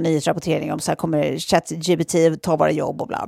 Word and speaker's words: ChatGPT: att på franska, då, då alltså uttalar man ju det ChatGPT. Vilket också låter exakt som när man som på katt. franska säger, Eller? säger eh, eh, katt jag pruttade.